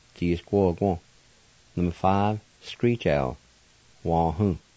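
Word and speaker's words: wahu: Number five, screech owl, wah-hoo, wah-hoo, wah-hoo.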